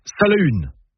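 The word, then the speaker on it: Salaün: Déjà, en breton, on prononce Salahune. Pas Salin, mais Salahune.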